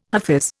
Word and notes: hafiz: Indian

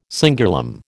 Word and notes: cingulum: American English

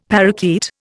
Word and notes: parakeet: Indian

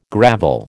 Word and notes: gravel: American English

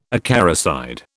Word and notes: acaricide: British English

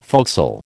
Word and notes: forecastle: American English

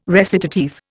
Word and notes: recitative: Indian

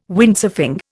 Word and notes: Windsurfing: Indian

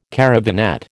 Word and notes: caravanette: American English

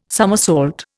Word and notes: summersault: Indian

